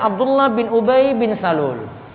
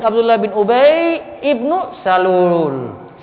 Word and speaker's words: Abdullah bin Ubay ibnu Salul: Lalu Abdullah bin Ubay ibnu Salul. Itu cara bacanya itu Abdullah bin Ubay ibnu. Di situ hamzah wasalnya dibaca. Jadi bukan dibaca Abdullah bin Ubay bin Salul.